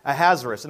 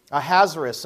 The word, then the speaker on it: Ahasuerus: Ahasuerus, okay, Ahasuerus. It sounds hazardous, but it's Ahasuerus. In the first year of Darius, the son of Ahasuerus, I like that, by descent a Mede, who was made king over the realm of the Chaldeans, that's Babylonia.